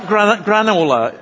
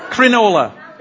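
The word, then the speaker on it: Cronulla: But it's, is it, what, how do you pronounce it? So it's not granola, it's crinola.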